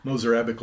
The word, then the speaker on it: Mozarabic: It's called Mozarabic. There you go. That's how you say it. Mozarabic language.